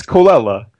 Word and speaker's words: Colella: Yeah, it's Colella. It's Italian.